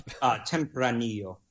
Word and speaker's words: Tempranillo: Say it one more time. One more time. – One more time. – I keep cutting you off. – Tempranillo. – There we go. Perfect. – Okay. Tempranillo.